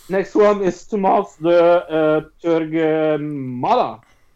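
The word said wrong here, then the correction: Tomás de Torquemada: Tomas de Torquemada? – Yes.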